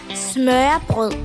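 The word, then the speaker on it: smørrebrød: Называется это блюдо смюреброд, как а, это произносится по-датски. По-датски это произносится как «смёпл».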